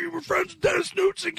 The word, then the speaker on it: Knudsen: Our engineer is Dennis Knudsen, but it's just pronounced Knudsen. The N is silent.